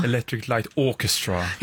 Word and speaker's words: Electric Light Orchestra: Vad bra uttal där. – Vadå? – Electric Light Orchestra. – Ja, men det är ju jag som lärde mig engelska på 1900-talet i skolan. Då fick man absolut inte prata med en amerikansk accent. Utan då var det British English som gällde.